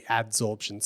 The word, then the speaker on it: adsorption: I think I said absorption before, yeah. So it's actually adsorption.